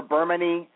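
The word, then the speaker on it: Bermane: I think you can pronounce Burmain or Burmany, and the last name is DeVerne.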